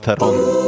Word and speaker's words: Theron: För det uttalas egentligen typ Theron eller någonting. – Front. – Ja. Charlize Theron. – Sitter ni ner? För nu kommer den. – Afrikansk. Charlize Theron.